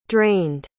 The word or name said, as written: drained